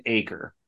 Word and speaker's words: Aker: The next guy up, and this is the correct way to pronounce his last name. Our guy Soup helped me out with it. It's Jackson Aker. So as in like an acre of land, it looks like an acre.